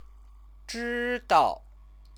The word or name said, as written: zhidao